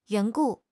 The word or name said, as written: 缘故